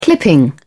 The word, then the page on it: clipping: British English